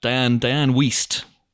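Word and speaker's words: Dianne Wiest: Diane Wiest. I said Diane Feast when I interviewed Rosamund Pike. I've never pronounced it that way before. I just panicked and went with a Diane Feast.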